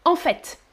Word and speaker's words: en fait: Most of the time, you pronounce the T, like in en fait.